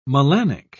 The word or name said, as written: melanic